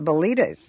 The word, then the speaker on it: Bilitis: You know, some people, when they hear the name, think it sounds like a disease, Bilitis. – Well, that's why we pronounced it Bilitis.